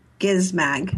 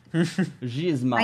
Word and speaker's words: Gizmag: This one came from Gizmag. – With a hard G. – Hard G. – Mm-hmm. – Craig was trying to trap you. – No, I just... – I know. I saw that. – Gizmag.